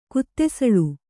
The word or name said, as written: ಕುತ್ತೆಸಳು